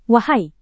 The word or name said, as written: wahai